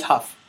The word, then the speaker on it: Hough: Julianne Ho, I think, is like one of those problematic. – Huff. – Huff. Yeah. Huff. Did you just say Ho? – Yeah. I don't know how to pronounce. it. I think it's yeah, Julianne Hough.